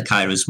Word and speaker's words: Kairos: So what is the Kairos moment and how those moments probably define the success of a person? Can you go a bit deeper into that? – So Kairos is the pronunciation.